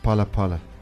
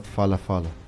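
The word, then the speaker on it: Phala Phala: And so it's Palapala. – Yes. – Not Falafala. P-H-A-L-A. – Yes. – Okay. – The H is silent.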